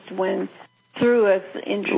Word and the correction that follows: Thoth: When Thoth, that's Thoth, actually. – That's Thoth.